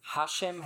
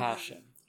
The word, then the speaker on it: Hashem: Hashem has emphasis on the first. – Hashem. – Mm-hmm. Hashem.